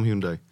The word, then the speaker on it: Hyundai: Takže pokud mě se zeptáte, jak já to říkám, tak já říkám Hyundai a je to fajn,